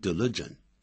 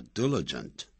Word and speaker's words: diligent: 我们也可以采用形容词 diligent，不要念成 diligent，叫 diligent，也一样的造出相同意思的这种句构。